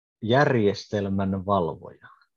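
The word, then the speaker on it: järjestelmänvalvoja: Uh, what, what was the the how is it pronounced? – It's uh, järjestelmän valvoja.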